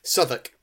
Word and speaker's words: Southwark: Southwark.